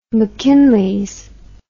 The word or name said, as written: mackinlays